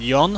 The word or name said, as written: jon